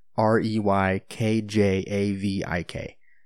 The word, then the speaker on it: Reykjavik: it's spelled R E Y K J A V I K, but it's pronounced Reykjavik.